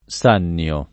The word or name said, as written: Sannio